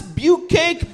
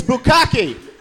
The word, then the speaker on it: bukkake: If you have one of those nerd friends that's not pronouncing bukkake correctly, like, it's bukake, bukiki, beat it, nerd, it's bukkake.